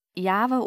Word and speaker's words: Jahwe: Und so kommt man dann zu der Aussprache Jahwe oder Jahwe oder auch Jehova.